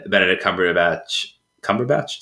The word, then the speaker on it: Cumberbatch: There's a bone-chilling scene of Kirsten Dunst playing the piano and Benedict Cumberbatch, did I say that right? – Yes.